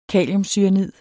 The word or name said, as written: kaliumcyanid